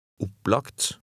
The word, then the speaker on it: Opplagt: At the end of some words, as in number 11, G in front of T is pronounced like a K. – Les nummer 11. – Opplagt.